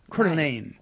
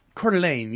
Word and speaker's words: Coeur d'Alene: Coeur d'Alene. You spell it C-O-E-U-R and then space and then a D and a little French uh-huh. dashy thing and then A-L-E-N-E. Coeur d'Alene.